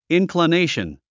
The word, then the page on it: inclination: ìnklənéiʃən; インクラネイション